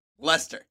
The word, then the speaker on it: Leicester: Leicester. Leicester. Oh, it's British pronunciation. – D-E-R-B-Y. Oh, my goodness. – Is Darby. – It's spelled L-E-I-C-E-S-T-E-R, so you can see I can make that mistake.